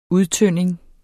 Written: udtynding

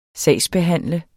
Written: sagsbehandler

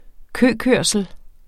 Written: køkørsel

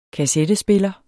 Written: kassettespiller

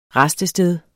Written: rastested

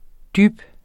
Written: dyb

